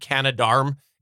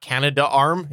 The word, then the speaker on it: Canadarm: It's actually Canadarm. It's Canadarm. It's not Canada Arm. It's Canadarm. So I've talked about it before, about how it was an important thing for the space shuttle.